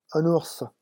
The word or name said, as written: un ours